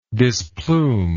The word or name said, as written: displume